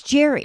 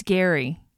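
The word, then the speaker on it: Gerry: It's Jerry. – It's Gary. Gary, but it's spelled Jerry.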